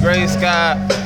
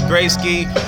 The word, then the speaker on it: Grasky: My name is Gray Sky. No need to ask why. They always say it's Gray Ski, but I ain't no German guy. However you pronounce that shit, it's just G-R-A-S-K-Y.